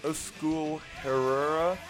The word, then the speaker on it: Euskal Herria: I think it is a city in Basque Country, which is, I can't remember, but I think it was like Euskal Herria. – Okay, is the actual pronunciation in Basque.